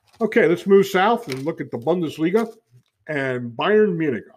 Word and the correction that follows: Bundesliga: I should say Bundesliga. I don't want to apologize to my German friends for that mispronunciation. Let's say Bundesliga and Bayern Munich at 45 points leading.